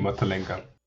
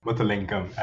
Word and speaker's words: Muttalingam: It's actually Muttalingam. – Muttalinga? – Muttalingam, as like with an M in them.